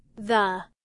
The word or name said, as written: the